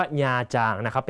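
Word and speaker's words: Nha Trang: ญาจางนะครับพ่อของน้องก็ชอบไปญาจางคำนี้ต้องอ่านให้ถูกนะฮะอ่านว่าญาจางนะ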